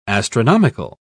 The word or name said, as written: astronomical